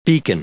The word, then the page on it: beacon: noun